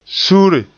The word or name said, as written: suure